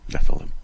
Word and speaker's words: Nephilim: I think I'm saying that correctly. – Nephilim, yeah.